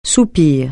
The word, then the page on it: soupir: francais